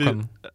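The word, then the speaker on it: Cocoon: Э, окей, это игра Какун. Это зомби... – Кокон. – Э, кокон. Да, наверное, лучше на русском это говорить. Это Кокон, это зомби Builder Def...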